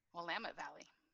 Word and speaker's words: Willamette: She lives with her husband and two daughters among the plentiful trees and clouds of the beautiful Willamette Valley. Did I say that right? – Willamette Valley. – Willamette, yeah. It's never pronounced how it's supposed to be.